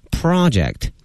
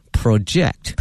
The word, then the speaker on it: project: 词汇畅谈，那么首先把这个单词给大家拼写一下 project, 它的发音大家小心了，作为名词呢是我们刚才发的这个音 project, 但是哈，两个但是大家注意了，如果是动词的话，它的发音发生了变化，它的重音从第一个音阶挪到了第二个音阶上，变成了 project, 哎 project, 所以名词是 project, 动词是 project。